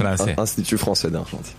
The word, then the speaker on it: Institut Français d'Argentine: Tenemos la primera francesa junto al lo podrá pronunciar bien, Institut François d'Argentine. ¿Lo dije bien? – Institut Français d'Argentine. – No lo dije.